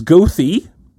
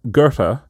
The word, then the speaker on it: Goethe: which people in Chicago pronounce Goethe. And we would all associate with the famous German author Goethe.